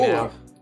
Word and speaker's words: amour: Can you spell it? – A-M-O-U-R? Amour. – Amour? – Amour. It's amour. Like... – Where's the I? Yeah, amour. Where's the I coming? – O-U-R. O-M-O-R.